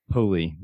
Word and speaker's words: Poli: So on today's episode, we have Angelo Poli.